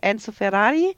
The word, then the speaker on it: Enzo Ferrari: genau, ist ein Biopic über Enzo Ferrari. – Oh, da hast du es sogar richtig ausgesprochen, Mensch, das ist ja Respekt. Das ist leicht mit dem italienischen Zungenschlag hier, das war ja super.